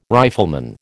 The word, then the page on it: rifleman: American English